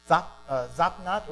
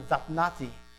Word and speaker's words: Zaphnath: And it's very different than what we typically say in our English, but Zaphnath or Zaphnati is kind of how you would say it in the Hebrew.